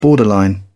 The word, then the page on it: borderline: British English